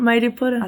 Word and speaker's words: Mairiporã: É, Mairiporã.